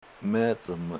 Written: 7mEEc$im

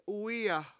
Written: 7uwIIya

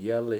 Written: yAAli7

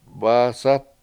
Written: bAAsat

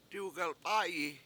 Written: diwgalp$A7yi

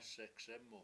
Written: SekSEmu

